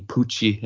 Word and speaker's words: Puig: due to the Catalan pronunciation of his name being Pucci.